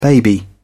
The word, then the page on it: baby: British English